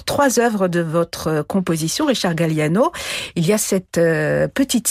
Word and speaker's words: Richard Galliano: Un extrait de la petite suite de Richard Galliano, joué par Richard Galliano avec le South Czech Philharmonic, dirigé par Miran Vaupotic. Je ne sais pas si c'est comme ça qu'on prononce Richard Galliano. – Oui, c'est ça.